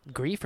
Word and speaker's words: Greef: Greef or Griff? – I believe it's Greef. That's how he pronounced it on stage.